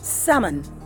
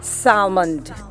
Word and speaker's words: salmon: How do you pronounce the word S-A-L-M-O-N and A-L-M-O-N-D? I can guess, but here's how it's pronounced. Summon. Summon, not salmond. Summon or almond. Or you say almond. Almond, like an almond. So all you need to do, make sure that the L is silent.